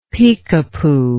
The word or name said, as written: peekapoo